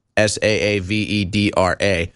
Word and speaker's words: Saavedra: I'm not sure how to pronounce it. It's Saavedra.